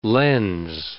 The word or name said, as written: lens